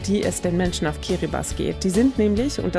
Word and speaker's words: Kiribati: Und wir haben eine Geschichte aus Kiribati, korrekt ausgesprochen Kiribati, wie wir ja beide gelernt haben heute.